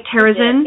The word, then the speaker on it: Terezin: Did I say that right, Terezin? – Yes.